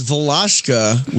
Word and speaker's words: Valashka: I was saying Valeshka. – And Pleiadian protege. – More importantly. It's Valashka, which rhymes with, I'm going to remember, it rhymes with slosh. Posh and mosh.